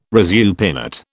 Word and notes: resupinate: British English

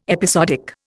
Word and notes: episodic: Indian

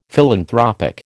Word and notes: philanthropic: American English

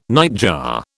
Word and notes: nightjar: British English

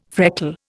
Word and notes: freckle: Indian

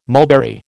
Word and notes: mulberry: American English

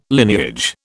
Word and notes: lineage: British English

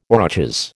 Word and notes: aurochs: American English

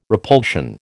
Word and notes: repulsion: American English

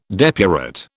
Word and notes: depurate: British English

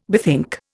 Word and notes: bethink: Indian

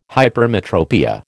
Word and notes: hypermetropia: American English

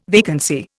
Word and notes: vacancy: Indian

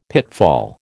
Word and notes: pitfall: American English

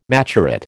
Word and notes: maturate: American English

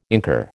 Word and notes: incur: American English

